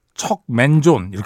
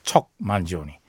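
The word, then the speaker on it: Mangione: 처음에 이 양반 이름을 사실 우리는 척맨존 이렇게 불렀거든요. 척맨존. 예. 제가 배철수의 음악 캠프 출연했을 때 물어봤잖아요. 정확하게 발음이 어떻게 되냐 그랬더니 만지오니가 맞다. 만지오니. 예. 척 만지오니.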